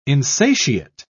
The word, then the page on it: insatiate: British English